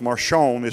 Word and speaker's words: Marcion: It's actually a person, but his name is just called, it's pronounced Marcion. It's spelled Marcion.